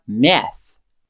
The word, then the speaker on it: math: E essa primeira parte que a gente vai trabalhar, math, M-A-T-H. Percebe que tem um TH no final, M-A-T-H. Essa é aquela pronúncia que nós já fizemos menções algumas vezes, que ela é somente um sopro.